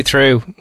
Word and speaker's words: Theroux: I call him Louis Troux with a silent X.